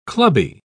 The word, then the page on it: clubby: British English